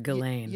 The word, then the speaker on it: Ghislaine: Unfortunately, so did his girlfriend, who I'm not sure if I'm going to say her name right, which is, I think, is Ghislaine Maxwell. – That's what I heard, Ghislaine.